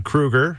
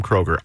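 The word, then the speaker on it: Kroeger: Dad Kruger from Nickelback says his name has been mispronounced for years. People have always called him Kroger.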